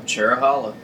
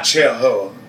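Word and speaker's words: Cherohala: Say it right this time. Cherahala. – Cherahala. Not Cherahoa. Right.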